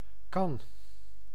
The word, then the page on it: kan: /kɑn/